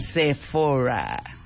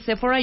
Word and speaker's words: Sephora: Sephora con acento en la E.